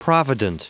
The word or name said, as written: provident